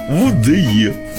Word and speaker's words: УДЕ: УДЕ. Да. Надо так произносить.